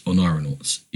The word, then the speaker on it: Oneironauts: But I don't know how to say it. Oneironauts? It's Oneironauts. Oneironauts. Okay, cool.